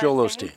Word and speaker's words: Joel Osteen: Yeah, Joel Osteen.